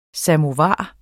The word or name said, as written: samovar